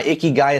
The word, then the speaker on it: ikigai: That word is spelled I-K-I-G-A-I, ikigai.